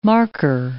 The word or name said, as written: marker